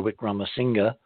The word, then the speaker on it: Wickramasinghe: For you new listeners, and thank you to Clyde Lewis for an extraordinarily fun show last night on his show with Chandra Wickramasinghe. I discovered something interesting. You pronounce the G-H-E at the end. I never knew that.